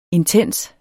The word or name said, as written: intens